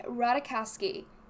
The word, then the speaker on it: Ratajkowski: Emily Ratajkowski can go Ratajkowski? Ratajkowski? – Ratajkowski. I'm pretty sure the J is silent.